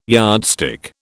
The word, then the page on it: yardstick: British English